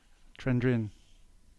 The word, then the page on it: Trendrine: English